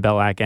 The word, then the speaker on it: Belack: My last name is spelled B-E-L-A-C-K, despite everyone's poor pronunciation thereof. So if you just type in Ben Balak anywhere, I'm right there.